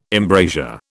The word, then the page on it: embrasure: British English